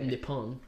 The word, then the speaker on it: Nippon: I don't think it's pronounced Nippin. – I think it's probably okay. Nippon or something French.